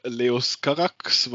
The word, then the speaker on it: Leos Carax: Genau, das ist ein Film von Leos Carax, der wirklich so ausgesprochen wird.